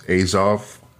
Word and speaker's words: Azov: I think most of the world thinks it's bullshit, but But in the Ukrainian military, I believe the way to pronounce this is Azov.